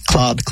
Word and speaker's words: Claude: You know, I think you can kind of bend it so that it sounds like cloud. I mean, how are you going to remember it? Otherwise, it's going to stick in your head as cloud. Like, Claude, cloud. – Okay. – It rhymes.